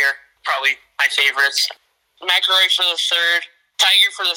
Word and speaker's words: McIlroy: Rory McIlroy. – McIlroy, there we go.